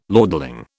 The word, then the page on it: lordling: British English